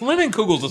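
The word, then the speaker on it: Leinenkugel's: It's Linenkugels. It's not hard.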